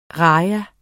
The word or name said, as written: rajah